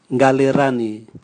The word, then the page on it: ngali rani: Raga